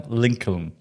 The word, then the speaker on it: Lincoln: Abraham Lincoln.